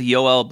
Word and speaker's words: Joel: Then let's go to Joel or Yoel Blomqvist. How do you say his first and last name? – I was going with Yoel Blomqvist.